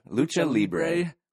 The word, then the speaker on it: Lucha Libre: Lucha Libre.